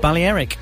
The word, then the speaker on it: Balearic: ...has vowed direct action after accusing the travel industry of destroying Barcelona and the Balearic Islands. Is that how you pronounce that? – Yeah, we're not going there.